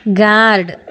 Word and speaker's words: guard: GUARD ಗಾರ್ಡ್, BALM ಬಾಮ್, PALM ಪಾಮ್, ಈ ಥರದ ಒಂದಷ್ಟು ಪದ ಹೇಳಿದೆ. ಇಲ್ಲಿ ನೋಡಿ, GUARD ಗಾರ್ಡ್ ಆರ್ ಉಚ್ಚಾರ ಆಗಲಿಲ್ಲ, PALM ಅಲ್ಲಿ ಎಲ್ ಸೈಲೆಂಟ್ ಆಗ್ಬಿಡ್ತು.